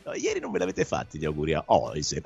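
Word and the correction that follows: Huijsen: anzi a Hausen, come ha detto che si pronuncia giustamente Camillo, che mi sta diventando un po' come Franchi di Tutto Sport, vuole le pronunce corrette.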